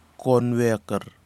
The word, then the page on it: konweker: kwamera